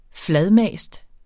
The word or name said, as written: fladmast